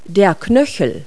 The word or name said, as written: ankle